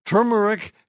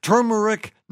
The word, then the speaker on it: turmeric: Turmeric has two R's and is pronounced turmeric, not turmeric.